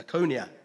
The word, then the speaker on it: Lycaonia: Don't know what that actually, I can't pronounce that word. Does anybody know? – Lycaonia. – Lycaonia, there you go.